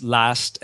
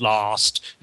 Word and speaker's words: last: No, those words are not pronounced that way in British English. It's last and stand, not lost and stand.